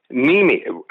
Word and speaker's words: Niemi: Ryan Nemi, I think is how you pronounce it, N-I-E-M-I.